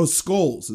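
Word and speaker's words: Schols: Oh, Scholes is how it's pronounced. Sorry. Okay. It's S-C-H-O-L-S, but when I...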